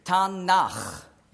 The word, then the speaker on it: Tanach: Das sind eigentlich drei Konsonanten. Das ist eine Abkürzung für Torah, Neviim und Ketuvim. Oder weich ausgesprochen, Ketuvim. Also Tanach, ja? T-N-Ch.